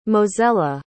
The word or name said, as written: mozella